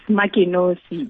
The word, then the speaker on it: Makenkosi: It's Makenkosi.